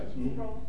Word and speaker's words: Sproul: Sproul, mm-hmm.